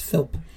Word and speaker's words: Philp: I wanted to say Philip, but it only has one I. – Yeah, you're right. – Yeah. – I just noticed that as you were saying. I was like, wait, how did you say that? But you're right. It is. It's Philp.